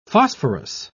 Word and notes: phosphorous: British English

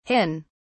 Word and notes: hin: British English

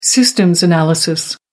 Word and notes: systems analysis: American English

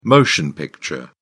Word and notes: motion picture: British English

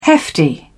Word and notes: hefty: British English

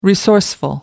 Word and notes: resourceful: American English

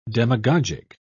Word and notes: demagogic: British English

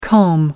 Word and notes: com: American English

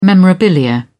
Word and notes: memorabilia: British English

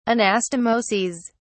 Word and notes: anastomoses: British English

